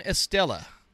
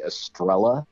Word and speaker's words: Estela: Um, a lot of people screwed up. They say Estrella. There's no R in there. There's no double L in there.